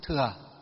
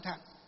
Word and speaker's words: thừa: Chúng ta xem những cái bộ phim ấy, pháp môn đại thặng của Phật pháp, họ đọc không có sai vì cái chữ đó là chữ thặng. Nhưng mà Phật giáo chúng ta đọc là gì? Chữ thừa. Phật giáo chúng ta phiên âm và đọc là chữ thừa. Còn ở ngoài đời họ đọc là chữ thặng.